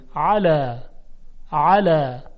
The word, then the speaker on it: علا: خب علا با عینه، حالا به تلفظ هر دو توجه کنید، اول علای با همزه، الا الا، حالا علای با عین، علا علا. ببینید چقدر تفاوت صدا هست.